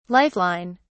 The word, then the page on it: life line: British English